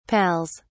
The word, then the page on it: pals: British English